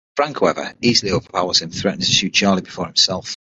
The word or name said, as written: overpowers